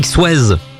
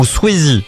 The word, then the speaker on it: Swayze: Sur Radio Star, la BO de Dirty Dancing avec Patrick Swayze ou Swayze. Oui, c'est vrai, on peut dire les deux. Il y a toujours un dilemme d'ailleurs avec la prononciation. – Radio Star. – Disons qu'en France, voilà, on est plus sur la première version, Patrick Swayze.